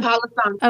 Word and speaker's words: Palo Santo: Yep, the Palo Santo.